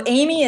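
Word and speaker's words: Amie: So Amy is spelled A-M-I-E-E. So I know that's totally weird. A-M-I-E-E. And then Mueller looks like Mueller. M-U-E-L-L-E-R.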